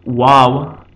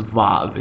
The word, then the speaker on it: Vav: And in modern Israeli Hebrew and in Ashkenazic dialect and in the Sephardic dialect, Wow is pronounced Vav. It's pronounced as a V in almost every circumstance unless it contains the U or O vowels. Then it is pronounced accurately as a W sound.